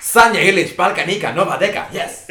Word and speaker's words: Sanja Ilić: We're going to Serbia, and it's Sanja Ilić and Balkanika with the song Nova Deka.